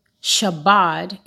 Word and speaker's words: Chabad: So when I looked into it, Shabbat, it is spelled C-H-A-B-A-D, is not just a website that shows Jewish festivals and the Jewish calendars.